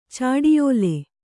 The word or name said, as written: ಚಾಡಿಯೋಲೆ